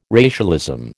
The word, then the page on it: racialism: American English